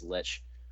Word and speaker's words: Lich: How do you pronounce that, by the way? Like Prince? – Lich Prince. – Lich? Lish? – I've always heard it pronounced as Lich.